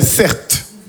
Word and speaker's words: Certe: Je m'appelle Certes. Mon prénom, c'est Certe. Ça s'écrit C-E-R-T-E.